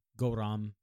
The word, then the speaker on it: Gouram: And it's, I don't exactly know how it's pronounced, but it's like Goram, G-O-U-R-A-M. So it could be Goram? – Goram.